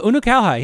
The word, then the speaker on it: Unukalhai: Very close. Unakalhai, yeah. – Unakalhai, yeah. – Okay. Wow, good job. – I got there. – You got that one. That's one of the... – The syllables in that one are cleanly defined.